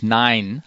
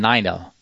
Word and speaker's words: Neiner: Also zum Beispiel, dass es halt äh, statt äh, die, für die Zahl neun nicht Nein heißt, wie das deutsche Nein, sondern Neiner.